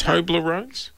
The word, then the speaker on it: Toblerones: Him to get me one That's of those giant Toblerones? – Oh, you've learned how to say it.